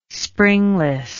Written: springless

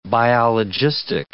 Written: biologistic

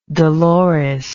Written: Dolores

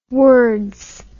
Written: wards

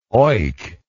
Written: oik

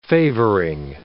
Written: favoring

